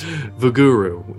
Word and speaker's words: Vuguru: Vuguru. It's V-U-G-U-R-U.